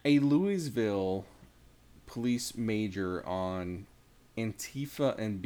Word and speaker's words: Louisville: First of all, it's Louisville. They don't even know how to say it.